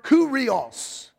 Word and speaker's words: kyrios: Now, the reason I'm using this scripture to illustrate this, the word Lord comes from the Greek word kurios. Kurios, but it's spelled K-Y-R-I-O-S, and it means controller.